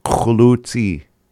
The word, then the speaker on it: Qulutsi: Khulutsi. Khulutsi. And sorry, I don't have the recording for that, but khulutsi. You can hear me say it. It is spelled Qulutsi. Khulutsi.